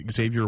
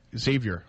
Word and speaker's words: Xavier: I've liked Xavier Ouellette, who was a late-round deal, I think, Xavier. – At this point. Xavier, pardon me. – No X, Xavier. – Xavier. Oh, yeah, you're right. That's right, he pronounces it that.